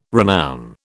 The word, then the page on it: renown: British English